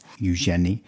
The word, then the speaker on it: Eugenie: And you are playing Princess Eugenics. – Yeah, yeah. Well, the S is silent, but yes. – Eugenie.